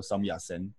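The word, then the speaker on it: Sun Yat-sen: 所以说你在坐捷运站的时候会听到那个中正纪念堂叫做 Sam Ya Sen，他就是孙逸仙的广东话。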